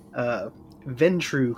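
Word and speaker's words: Ventrue: He is a member of the Ventru clan. Am I saying that right? – That is correct.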